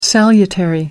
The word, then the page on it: salutary: American English